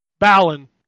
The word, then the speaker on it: Balan: It's called Banal Wonder World, right? – Balan Wonder World, sorry. See, again, even I messed it up, and I knew that people had been messing it up.